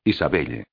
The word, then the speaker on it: Isabelle: No, se pronuncia Isabel. Ya, Isabelle. Isabelle es en la lengua de mi país.